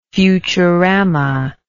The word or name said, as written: futuramic